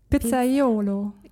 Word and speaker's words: Pizzaiolo: Kennst du diese typischen Pizzabäcker aus Italien, die Pizzaiolo.